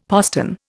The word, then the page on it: postern: Indian